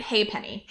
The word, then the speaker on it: halfpenny: How do you pronounce that? – Halfpenny? I always heard it hey penny. – Hey penny. – Like H-A. – Like hey penny opera? – Yeah.